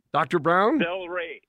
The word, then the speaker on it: Cel-Ray: Celery, Dr. Brown? – Celery. Yep, spell it was C-E-L-R-A-Y.